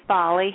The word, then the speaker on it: Folly: Hey, my name's Folly.